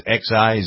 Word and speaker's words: Xi: Why doesn't Trump and, what's his name, Mr. Xi, how do you pronounce it? X-I, Xi, I guess it is, right? – I'd say yes to that.